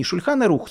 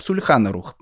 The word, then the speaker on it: Шульхан Арух: Так и было написано в деле, не «Шульхан Арух», «Сульхан Арух». Я думаю, что Станисов Костинский уже сам забыл, как читается на иврите, но не суть важно.